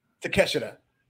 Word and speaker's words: Takeshita: Wait, how's it pronounced? – Takeshita. – Oh, Takeshita, not Takeshita.